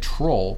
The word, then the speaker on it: troll: The variant troll, pronounced tro, in most of Brazil, is the usual term to denote internet trolls.